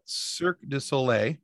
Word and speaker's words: Cirque du Soleil: but Cirque du Soleil.